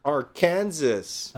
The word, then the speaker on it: Arkansas: states of Arkansas, Missouri, Oklahoma, and Kansas. – Arkansas. – Arkansas.